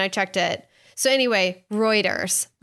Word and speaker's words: Reuters: I didn't realize I just pronounced it wrong and I checked with a friend. So I said, Reuters. It's Reuters.